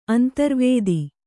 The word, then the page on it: ಅಂತರ್ವೇದಿ: antarvēdi